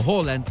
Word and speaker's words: Haaland: Sí, después de todo el fenómeno que trajo Erling Haaland, o Haaland, como se pronuncia en su país, pues bueno, el asunto se puso de locura.